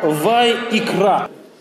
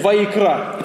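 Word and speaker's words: Вайикра (וַיִּקְרָא): По-еврейски она произносится как «Вайикра». Если хотите блеснуть где-нибудь эрудицией, можете сказать «Вайикра».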